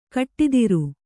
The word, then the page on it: ಖಡತರ: khaḍatara